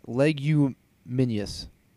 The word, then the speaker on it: leguminous: L-E-G-U-M-I-N-O-U-S. You say that. Leguminous plant, such as the pea, splitting open on both sides when ripe. Leguminous. That's how you say it.